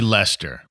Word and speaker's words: Leicester: He's from Leicester or something. He's called the Jester from Leicester, whatever. – No, that'd be Leicester. – Leicester. – Jester from Leicester. See, it rhymes.